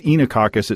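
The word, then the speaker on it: Oenococcus: And it's, you say it's Enococcus. It's spelled Enoccus.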